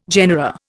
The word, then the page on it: genera: Indian